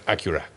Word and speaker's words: Acura: Viene de accurate en inglés, entonces... Ajá. En Estados Unidos la pronunciación correcta es Acura.